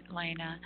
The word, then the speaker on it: Lana: Well, well, thank you, Chuck, and thank you, Lana, for being with us. Is it Lena or Lana? – Um, it is actually Lena.